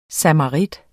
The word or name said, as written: samarit